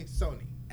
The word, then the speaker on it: sonni: They told me Sony, S-O-N-N-I.